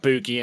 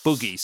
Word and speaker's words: Boogie: Got an A in it rather than an E. Mesa, and Boogie. So they say an A instead of an E and pronounce it as if there's one O and not two O's, and we're wrong.